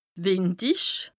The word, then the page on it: wìndich: Bas Rhin; Strasbourg